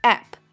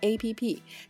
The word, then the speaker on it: app: 第六个，app，它的正确读音是 app。而不是我们生活中常说的 A P P，它是 application 的缩写，指手机中的应用程序。那今后大家就记住它的正确念法是 app，不要再说 A P P 了。